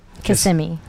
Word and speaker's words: Kissimmee: Kissimmee.